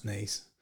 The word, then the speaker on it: Nice: there's a tweet that's quite funny because obviously Nice is pronounced Nice.